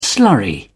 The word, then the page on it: slurry: British English